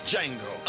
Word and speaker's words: Django: Tyrese Gibson is Django unlotion. The D is silent.